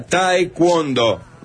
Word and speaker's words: taekwondo: Se pronuncia taekwondo.